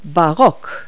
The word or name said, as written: baroque